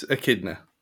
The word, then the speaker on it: echidna: So Idris Elba, he's going to be hot off the Suicide Squad, and he's been brought in to play the Is it echidna or echidna? How do you pronounce it? – It's echidna. – It's echidna, right, okay.